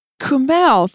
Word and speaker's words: Kumail: Kumail, thank you for joining us.